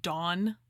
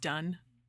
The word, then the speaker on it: Donne: John Donne looks like Dawn, D-O-N-N-E, but it's pronounced Dunn.